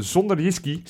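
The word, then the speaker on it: Sønderjyske: En die heeft eh, de 2-0 gemaakt, wat uiteindelijk 2-1 gewonnen wedstrijd tegen Zonderjski. – Dat, dat spreek je lekker uit. – Lekker, Nog een keer? whisky. Ja, klinkt als zonder whisky, maar dan op zijn Deens.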